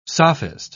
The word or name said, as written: sophist